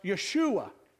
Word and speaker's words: Yeshua: Well, in the Hebrew, it's pronounced Yeshua.